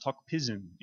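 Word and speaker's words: Tok Pisin: It's pronounced Tok Pidgin in Papua New Guinea.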